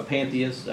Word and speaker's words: pantheist: Yeah, he's a what's called a panthesis. What is that? A pantheist, I guess you would actually pronounce it that.